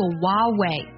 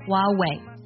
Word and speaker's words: Huawei: 它的英文名不少人会读作 Huawei，当然也有很多人啊，坚持把那个" 呵" 的音读出来，读作 Huawei。存在竞争里，这两种发音呢都是 acceptable 的，都是可以的。